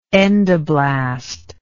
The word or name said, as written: endoblastic